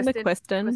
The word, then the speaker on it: McQuiston: That I think that was my first Casey McQuinton book. Is that how you say her name? McQu- I think McQuiston. McQuiston yeah. yeah.